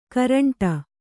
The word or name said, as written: ಗೋರಂಟೆ